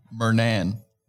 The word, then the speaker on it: Murnan: Murnan.